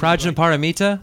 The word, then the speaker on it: Prajnaparamita: What is Pasha Malpita? I think I'm not pronouncing Prajnaparamita? – Right. Yeah, that.